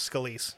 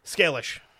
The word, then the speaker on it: Scalish: So after the death of John Scalise, who I didn't realize I'd been mispronouncing his name incorrectly, but the last name is Scalise. He was the head of the Cleveland family. – How did you pronounce it before? – Scalish. Because that's how it's spelled. It's Scalish. But it's pronounced Scalise.